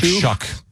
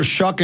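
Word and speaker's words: Shough: I think, it's like Shuck, even though it doesn't look like it. – Tyler Shuck and Jordan Tyson.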